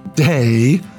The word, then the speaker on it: De: Her name is thought to be derived from De, pronounced Day, which is related to Gay, meaning earth. Okay, so that's D-E and G-E, Day and Gay.